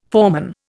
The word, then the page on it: foreman: Indian